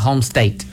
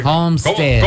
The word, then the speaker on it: homestead: Homestead, not homestate. – Bueno, pues homestead.